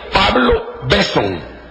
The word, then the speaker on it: Besson: La versión se llama versión Pablo Besson, con dos S's y sin acento en la O, porque si no sería Pablo Besson.